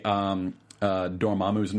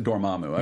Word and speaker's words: Dormammu: So I've had a couple people on Twitter ask me how to say Dormammu's name. I've always pronounced it Dormammu. I've heard it. I've heard other people recently pronouncing it Dormammu. – Dormammu, yeah.